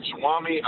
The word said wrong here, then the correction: Ramaswamy: Uh, yeah, uh, Ramaswamy, Vivek. – Ramaswamy, yeah.